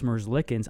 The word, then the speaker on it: Merzlikins: And then their backup, Elvis Merzlikins, I don't think they're going to throw...